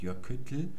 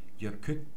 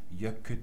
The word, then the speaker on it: Jökull: Man sagt also nicht Jöküttel, sondern man sagt Jökütl. Man stoppt also beim T und lässt dann nur noch die Luft an der Zunge entlang zu beiden Seiten entweichen. Jökull.